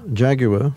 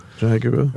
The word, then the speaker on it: Jaguar: Would you say that again, just so you can teach us how to say it? Pronounce the name? – Jaguar. – Thank you. – Jaguar. – Okay, Jaguar.